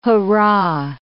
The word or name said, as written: hoorah